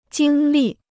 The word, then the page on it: 经历: jīng lì